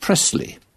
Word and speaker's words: Presley: Elvis Presley, or as the Americans pronounce it, Presley.